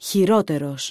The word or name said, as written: χειρότερος